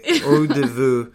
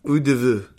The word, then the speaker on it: eau de vie: I'm going to spell it out for you guys, and you can definitely hit me up for butchering this, but it's spelled EAUX-DE-VIE. Eau de vie. That's what I'm going to go with.